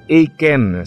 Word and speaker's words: eiken: Alam nyo, ang kahinaan sa Bible, ang word na ginamit ay Aiken. So E-I-K-E-N. Ayokong i-pronounce na Aiken kasi parang baliktad, ano? Aiken pero I cannot.